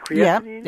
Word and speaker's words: creatinine: So this year is lower, but the creatinine, is that how you say it, creatinine? – Yep.